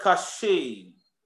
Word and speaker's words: qasheh: Now, that word stiff-necked is the word kasheh. It's spelled Q-A-S-H-E-H.